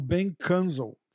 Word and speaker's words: Ben Kunzel: Ó, a gente se reuniu hoje aqui pra falar do que tá rolando com o Ben Kunzel. Falei certo o nome dele? – É isso mesmo, Benjamin Kunzel.